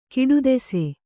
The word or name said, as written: ಕಡುದೇಸಿ